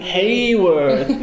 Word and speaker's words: Haworth: Haworth! Ja, het is een beetje Yorkshire accent, moet je denken aan Boromir in Lord of the Rings.